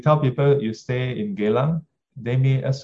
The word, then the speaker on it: Geylang: Last time, if you ask me where do I stay, then I will say Geylang, and say it very properly, Geylang. The correct pronunciation is Geylang.